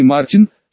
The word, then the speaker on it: Мартин: его звали Мартин, с ударением на последний слог.